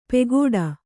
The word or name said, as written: ಪೆಗೋಡ